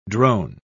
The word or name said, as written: drone